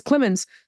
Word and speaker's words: Clemons: And it's Clemons spelled with one M, C-L-E-M-O-N-S.